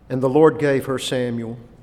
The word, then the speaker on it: Samuel: Now in Hebrew, that's pronounced Samuel. Samuel. El being the name for God.